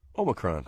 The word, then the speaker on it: Omicron: We know that, do I pronounce it Omicron or, that's how you pronounce it, right? Omicron or Omicron? – Omicron.